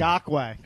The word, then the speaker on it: Ngakoue: Ngakoue.